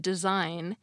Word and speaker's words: design: Although it can be tricky when we first learn to read and write that you don't pronounce the G in design, Leaving it there is useful in order to see the relationship between its cousin word, designate.